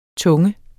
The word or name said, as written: tunge